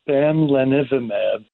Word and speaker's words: bamlanivimab: So the drug, I believe, is pronounced bamlanivimab.